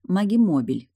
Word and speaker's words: магимобиль: Если быть точнее, магимобиль, ударение на букву «О».